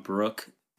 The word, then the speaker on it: Bruch: Trayvon Bruck, but it's spelled B-R-U-C-H.